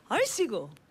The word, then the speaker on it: 얼씨구: Let's try 얼씨구. – 얼씨구. – Great. Or you can do that 얼씨구. – Oh, okay. – Yeah. So 얼씨구. Accent move to the second syllable.